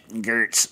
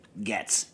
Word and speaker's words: Goetz: Getz is spelled G-O-E-T-Z. So it should be pronounced Gertz, but it's Getz.